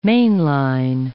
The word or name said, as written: mainline